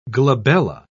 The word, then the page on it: glabella: British English